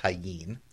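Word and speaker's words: Tajín: T-A-J-I-N. – It's spelt tagine, but it's pronounced tagine.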